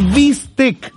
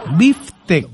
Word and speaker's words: bistec: El bistec, uno dice, hoy me voy a comer un bistec, y no es así. Porque uno debiera pronunciar como biftec. Así es, el bif, como bife. No es big time ni nada de eso, no, es biftec.